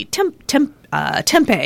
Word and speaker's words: tempeh: Kimchi, tempeh is how you say that.